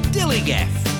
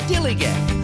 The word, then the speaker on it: dilligaf: And I can relate to their attitude too, in a word, dillygaff. That's spelled D-I-L-L-I-G-A-F, dillygaff.